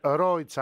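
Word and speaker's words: Roig: γιατί είναι ένα μεγάλο γήπεδο η Ρόιγκ Αρένα. Και εδώ το μόνο που δεν έχω ακόμα τσεκάρει, γιατί μου λένε οι ντόπιοι ότι μπορεί να προφέρεται Ρόιτς. – Άκουσε με, στο επιβεβαιώνω, λέγεται Ρόιτς Αρένα.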